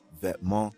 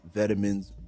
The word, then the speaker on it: Vetements: We had Kid Filthy with his latest single Vetement, which is the actual way to pronounce it, but you know we got to keep it Anglo-Saxon. So Vetements, brand new single by Kid Filthy.